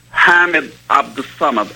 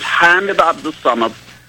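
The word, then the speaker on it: Hamed Abdel-Samad: like the name is Hamid Abdul Samad. I'm pronouncing it in Arabic. His name is Hamid Abdul Samad.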